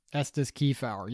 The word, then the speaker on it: Estes Kefauver: Estes Kefauver, yes. Estes Kefauver. Trust me, I had to look up pronunciation of the guy's name.